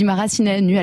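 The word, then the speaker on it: Maracineanu: Roxana Marasinianu, on dit Marasinianu à l'époque à la télévision française. C'est vrai que c'est le nous qu'il faut prononcer à la roumaine.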